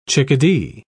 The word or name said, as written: chickadee